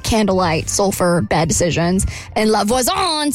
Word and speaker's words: La Voisin: So she meets this person named La Voisin.